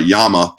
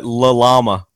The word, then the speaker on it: Llama: And uh, and Llama pistols out of, uh, or you know, if you want to say it right, Yama pistols out of Spain. – Is that how you say? It? I was always wondering. I always call it La Llama because there's yeah. two L's.